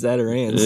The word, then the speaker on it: Zatarain's: Bro, yeah, it's Zatarain's.